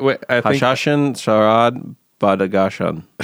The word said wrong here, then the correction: Badakhshan: And the album title is Badakhshan.